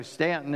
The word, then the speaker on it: Staunton: You can go to our website, CBC Stanton, and for those of you who may be listening, we don't spell that S-T-A-N, we spell that S-T-A-U-N-T-O-N, okay? So Stanton is how we say it, though.